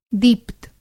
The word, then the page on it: दीप्त: Hindi